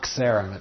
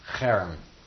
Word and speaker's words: cherem: Some people write it with a X, but it's not xerem, it's cherem.